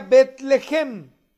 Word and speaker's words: Betlehem: Betlehem, o sea, lo que ustedes conocen como Belén, bueno, se pronuncia Betlehem, casa de pan.